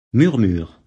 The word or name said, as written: murmures